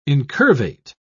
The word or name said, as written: incurvate